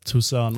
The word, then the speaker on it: Tucson: Ähm, ja, wollte ich nur klarstellen, ähm, dass, dass ihr nicht denkt, dass es so ausgesprochen wird, weil ähm, ja. ja, es heißt Tucson und es ist in wir Arizona.